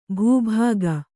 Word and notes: ಬೊಬ್ಬಿಕ್ಕು: bobbikku

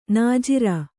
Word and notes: ನಾಜರು: nājaru

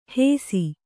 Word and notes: ಹೇಸಿ: hēsi

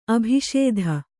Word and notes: ಅಭಿಷೇಧ: abhiṣēdha

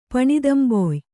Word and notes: ಪಣಿದಂಬೊಯ್: kannada

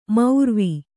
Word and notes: ಮೌರ್ವಿ: maurvi

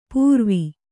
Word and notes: ಪೂರ್ವಿ: pūrvi

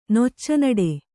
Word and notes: ನೊಚ್ಚನಡೆ: kannada

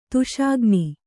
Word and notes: ದೋಷಘ್ನ: dōṣaghna

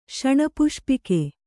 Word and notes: ಶಣಪುಷ್ಪಿಕೆ: Kannada

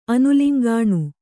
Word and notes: ಅನುಲಿಂಗಾಣು: kannada

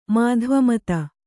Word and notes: ಮಾಧ್ವಮತ: mādhva mata

